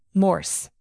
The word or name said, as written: morse